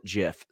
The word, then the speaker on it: GIF: Somebody says, hard G, not like Jif peanut butter. Hey, guess what? The person who created the file name calls it Jif.